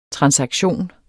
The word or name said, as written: transaktion